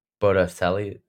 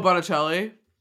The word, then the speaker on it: Botticelli: Botticelli? Is that how you say it? – Oh, Botticelli. – Thank you. I totally messed that up.